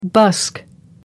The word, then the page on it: busk: American English